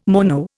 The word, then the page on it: mono: Indian